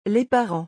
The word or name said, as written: Les parents